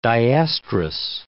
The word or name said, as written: diestrus